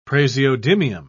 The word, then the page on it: praseodymium: British English